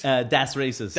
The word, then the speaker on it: Das Racist: Das Racist. – Das Racist.